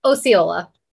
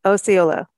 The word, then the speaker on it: Osceola: Can you say the name of the town again? Because apparently I really messed up again. – Osceola. – Okay, Osceola.